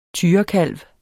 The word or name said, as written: tyrekalv